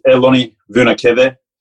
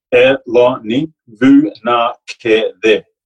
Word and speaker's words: Eloni Vunakece: So my name is Eloni Vunakece. Easiest way to pronounce Fijian names is chunky letters like consonant and your vowels, and they all roll off the tongue really well.